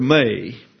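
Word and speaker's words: may (μή): Not is, in the Greek, the word may, M-E. That is an Ata, therefore pronounced as if it were a long A in English. So it's may, and that expresses the negation of the wish that follows.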